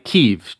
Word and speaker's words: Kyiv: And when I said it, I also had to say it as Kyiv, which isn't exactly right, but closer, I guess. I've also seen two different spellings of the city, one that seems older, like K-I-E-V, and the one that I've seen more recently, K-Y-I-V.